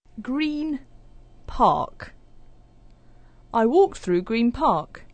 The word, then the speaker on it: Green Park: So the N sound at the end of green starts to sound like a M. So instead of Green Park, it's Green Park.